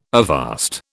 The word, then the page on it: avast: British English